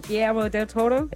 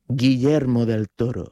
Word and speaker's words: Guillermo del Toro: Guillermo del Toro? – Yes, smashed it.